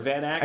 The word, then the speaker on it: Van Acker: Van Acker. – I said it. Did I say it right? – Van Acker. It means of the oak tree.